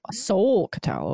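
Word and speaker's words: Solketal: How'd you spell that again? The Sol-Catal? – Is it S-O-K? – S-O-L. – S-O-L. Sol-Catal. Okay. – Yeah, and then K-E-T-A-L.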